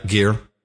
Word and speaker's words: Geir: So we've yet to thank Gear, G-E-I-R. Is that pronounced right? – Yeah, Gear.